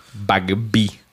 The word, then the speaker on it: Bagby: Bagby.